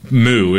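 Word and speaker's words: Mu: the Moo, is I guess how you pronounce it, but it looks like a U.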